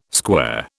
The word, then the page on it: square: British English